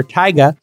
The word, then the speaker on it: Tyga: Okay, everybody, rapper Tyga, spelled T-Y-G-A, kind of pronounced like Tiger, but Tyga, deleted his OnlyFans account I saw on Instagram and started his own platform, MyStar, with two Ys.